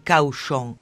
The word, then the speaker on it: Cauchon: Il vescovo Pierre Cauchon, che si scrive Cauchon, ma si pronuncia come porco, e il cardinale di Winchester, come un fucile che spara.